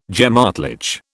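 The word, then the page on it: gemütlich: British English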